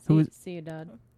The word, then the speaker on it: Ciudad: In a place like, and I'm going to mispronounce this, Ciudad Juarez. – Ciudad. – Whatever. – It's Spanish for city.